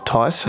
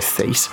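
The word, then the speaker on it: Thijs: Tice? That's how you pronounce it. Sometimes I just read it and go Thies. But Tice. I feel Tice is doing a lot of stuff.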